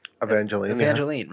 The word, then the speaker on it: Evangeline: So this girl that listens to my music, I think her name is Evangeline. I don't know how to pronounce it, but it's E-V-A-N and then Jeline. – Evangeline, right? – Sounds right. Yep.